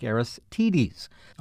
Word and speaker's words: Aristides: Well, I have had a bit of an email dialogue with Clay, who told me the correct pronunciation of what I said was Aristides was more like Aristides, a reference I found online from author Bill Doolittle, who has written about the Kentucky Derby, confirms the long I sound.